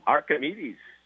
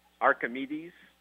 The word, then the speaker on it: Arquimedes: Archimedes, yes. – How do you say it? – Archimedes. – Okay, Archimedes Caminero.